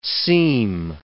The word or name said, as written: seam